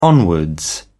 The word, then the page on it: onwards: British English